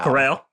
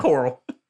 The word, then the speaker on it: Coral: Corral. Yeah, they say it right. It's Corral. – Cape Coral. – It is not Coral. This ain't SpongeBob.